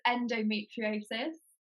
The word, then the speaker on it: endometriosis: Can you pronounce it for me? Because I just didn't know how to say it. – So it's endometriosis.